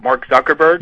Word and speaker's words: Zuckerberg: But I thought the young man who played, is it Mark Zuckerberg? – Yes.